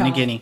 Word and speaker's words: Nagini: Yeah, Nagini.